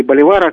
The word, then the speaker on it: Боливар: Ну, Симона Боливера или Боливара, как вы правильно говорите, убили золотой пулей.